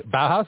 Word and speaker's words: Bauhaus: I actually Bauhaus. Is that how you pronounce it? – Yes.